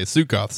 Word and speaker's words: Sukkoth: Yeah, I was about to say, Sukkoth sounds right.